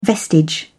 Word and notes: vestige: British English

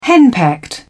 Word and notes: henpecked: British English

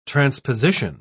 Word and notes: transposition: British English

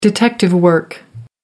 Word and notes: detective work: American English

